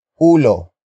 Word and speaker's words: ulo: The main topic is a focus phrase starting with ulo. Ulo. Ulo. Ulo is spelled as U-L-O.